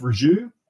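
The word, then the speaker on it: Rieju: Sam Rogers, he's moved from Sherco to, I think it's pronounced Raju.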